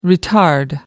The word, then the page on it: retard: American English